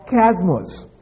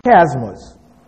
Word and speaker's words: chiasmus: Now, this is because some scholars believe that the apostle had used a figure of speech that they call chiasmus. Chiasmus. It's spelled C-H-I-A-S-M-U-S.